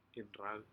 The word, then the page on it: iurac: Aneityum